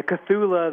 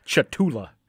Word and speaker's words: Cthulhu: And thank you for saying that, because I didn't think I was going to be able to pronounce that. I was looking at it like Chatula. – Sure. It's meant to be unpronounceable, and we're probably all saying it wrong, which is good, because if we said it correctly, we might call Cthulhu, and then we'd all be really unhappy.